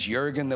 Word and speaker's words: Jurgen: I turn up at the hospital and just said, hey, you probably noticed my name is Jurgen. They're like, Jurgen. I said, I know it's spelled with a J, but it's pronounced.